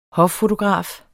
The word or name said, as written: hoffotograf